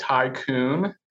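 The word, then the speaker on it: Thai Kun: And then one kind of wild card place that we found recently is called Tycoon in the Domain. So it's spelled T-H-A-I space K-U-N. – Oh. – I think it's kind of a play on the word tycoon, like the tropical storm, but it's just amazing Thai street food.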